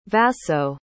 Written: vasso

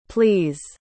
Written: pleas

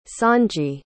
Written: sonji

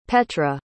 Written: petra

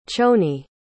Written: choni

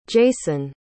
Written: jaeson

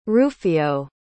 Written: rufio

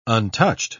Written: untouched

